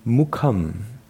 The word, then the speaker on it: mukham: Mukham. – Mukham. – Mukham. – Mukham. – Good. Mukham. – Mukham. – It's a very short U sound.